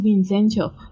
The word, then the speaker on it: Vincenzo: Sí, Vincencho está en Netflix, o sea, es Vincenzo en español, pero la pronunciación es Vincencho, según él.